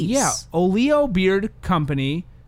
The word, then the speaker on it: Olio: And we got an email from the people at Olio Beard Company. Olio Beard Company. O-L-I-O with an accent on the I.